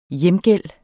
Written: hjemgæld

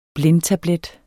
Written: blindtablet

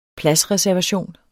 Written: pladsreservation

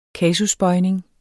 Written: kasusbøjning

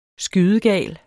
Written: skydegal

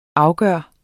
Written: afgjort

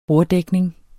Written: borddækning

